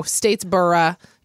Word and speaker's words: Statesboro: Thanks to listener Caroline, who grew up in Statesboro. Am I pronouncing that right? – Oh, I think that's definitely it. – Statesboro, Georgia.